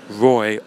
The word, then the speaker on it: Roy: I would typically introduce myself by saying my name is Roy, R-O-Y. Because since my youth, I have had issues with saying the letter R and pronouncing it correctly, which is rough enough on its own. Even more so when it makes up 30% of your name. So I would often get Ray or Rory, or just what?